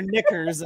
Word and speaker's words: knickers: And like, you know, they're wearing their knickers and they're like, whoa, my dad Scott, goes to careful. – The dive. Yeah, you heard me. I said knickers. – Silent K.